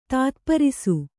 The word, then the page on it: ತಾತ್ಪರಿಸು: tātparisu